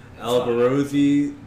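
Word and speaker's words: Alborosie: Albarosi dub. I think I'm pronouncing that right. – You are.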